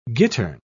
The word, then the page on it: gittern: British English